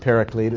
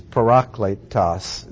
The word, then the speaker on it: parakletos: It's not paraclete, it's parakletos is the word, and it means to be called beside.